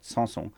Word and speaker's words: Sanson: Phonetically is her name is one word, Sanson. Like Sansom and Dalila.